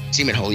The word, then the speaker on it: Seminole: It's actually pronounced Seminole, yes.